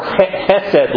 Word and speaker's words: chesed: chesed love. I have enough limit in my throat, I can say it right.